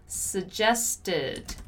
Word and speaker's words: suggested: We have suggested. So strong ED sound, right?